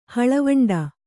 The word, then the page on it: ಹಳವಂಡ: haḷavaṇḍa